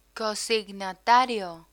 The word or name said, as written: cosignatario